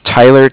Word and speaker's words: Tyler: My name is Tyler, T-Y-L-E-R. – Okay. – And I'm calling with Cardiff. It's spelled C-A-R-D-I-F-F, as in Frank Frank.